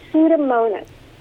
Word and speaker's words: Pseudomonas: Pseudomonas, which is a bacteria. Yeah, let me spell it. – Pseudomonas. – Yeah, there's a silent P. So P-S-E-U-D-O-M-O-N-A-S.